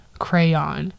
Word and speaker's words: crayon: So then the second one with two syllables, crayon. With two syllables where the second syllable rhymes with dawn.